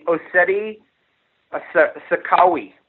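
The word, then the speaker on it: Oceti Sakowin: So we're thinking in the spring, if this is still going on, we'll go back there where all the it's the Ossetti Sakawi, I think is how it's pronounced. It's O-C-E-T-I. S-A-K-O-W-I-N, camp.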